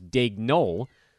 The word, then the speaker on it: Daigneault: It's spelled D-A-I-G-N-E-A-U-L-T. I actually would have thought it was Daigneault, but apparently the T is pronounced.